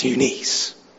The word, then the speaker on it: Eunice: I have been reminded of your sincere faith, which first lived in your grandmother Lois and in your mother Eunice. I've also heard it pronounced Eunice. If she's really nice, you could call her Eunice.